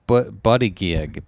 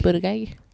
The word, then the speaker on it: Buttigieg: Pete Buttigieg, Buttigieg. – Buttigieg.